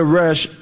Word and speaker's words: Resh: The word for the letter Resh is spelled Resh Yashin. It sounds like the Hebrew word Rosh, which means head.